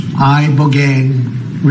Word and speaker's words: Ibogaine: Ibogaine. Remember the name. Is that pronounced relatively properly, would you say? – Yes. – I don't want to get it wrong. Ibogaine, because it's so important, and experienced an 80 to 90% reduction in symptoms of depression and anxiety within 1 month.